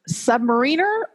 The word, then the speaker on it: submariner: What is the correct way to pronounce this word? Is it submariner or submariner? – Submariner.